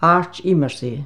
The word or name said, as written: AIRD IMERSAY